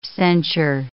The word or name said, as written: censure